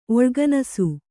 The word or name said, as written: ಒಳ್ಗನಸು